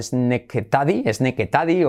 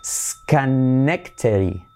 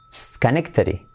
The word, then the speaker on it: Schenectady: Que diríamos algo así como Sneketady o una cosa similar. Pues hagan sus apuestas a ver cómo pronunciaríais esto y a ver si acertáis. Tiempo, pulsador. Se dice Schenectady. Schenectady. Schenectady. ¿Veis? La D última se hace como una R suave.